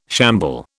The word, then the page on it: shamble: British English